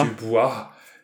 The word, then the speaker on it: Dubois: Vor ein paar Tagen das Konzert in Essen in der Du Boys Arena. Und das ist, ja, äh, ähm, Bitte? Nee, ich glaube, das wird französisch ausgesprochen. – Die Oha. Dubois.